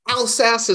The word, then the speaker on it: Alsace: Alsace is how I've always heard it pronounced.